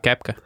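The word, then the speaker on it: Koepka: Ko Epka viņš rakstās. – Kepka. – Bet nu izrunā Kepka, kā Kepka.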